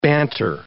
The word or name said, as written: banter